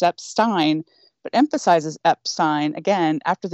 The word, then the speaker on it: Epstein: In September, we recorded a special, and then we did an addendum to that special about how to actually pronounce Brian Epstein's last name. And yes, it is Epstein.